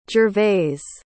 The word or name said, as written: gervais